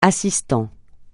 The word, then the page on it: assistant: francais